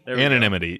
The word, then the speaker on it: anonymity: How do you say that word? – Anonymity. You know Anonymity. – Anonymity. – Anonymity. Go. Yeah, there we go.